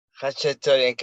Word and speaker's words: Khachaturian: Aram Khachaturian Concert Hall? – Khachaturian Concert Hall.